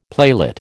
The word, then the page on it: playlet: American English